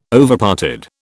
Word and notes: Overparted: British English

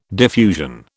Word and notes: diffusion: British English

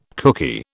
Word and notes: cookie: British English

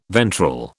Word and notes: ventral: British English

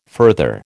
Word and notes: further: American English

